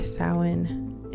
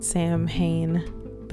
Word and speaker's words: Samhain: Samhain. It's not Samhain, but that's the way it looks, but that's not how you say it.